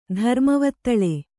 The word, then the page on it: ಧರ್ಮವತ್ತಳೆ: kannada